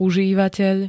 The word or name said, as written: užívateľ